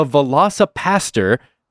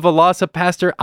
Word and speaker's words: Velocipastor: Joining us, though, is a writer-director of the film The Velocipastor. That's right. I said that correctly. It's called The Velocipastor.